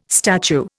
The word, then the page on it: statue: Indian